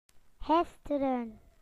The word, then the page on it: yesterday: British English